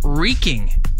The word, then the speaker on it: wreaking: In fact, she pulled her phone out, went to Google, and then clicked the pronunciation of the phrase. What do you think it said? Wreaking havoc. Because that's the phrase.